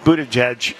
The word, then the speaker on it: Buttigieg: Now, so I guess Pete Buttigieg, it turns out that... – Good pronunciation.